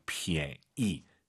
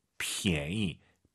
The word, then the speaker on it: 便宜: 便宜, 니은 발음 들리면 안 돼요. 便宜, 便宜, 便宜. 오히려 그 pn 발음에서 n, 니은 발음이 약간 무금된다는 느낌으로 便宜, 이것도 좋을 것 같습니다. 便宜 이렇게 하지 마시고요. 便宜.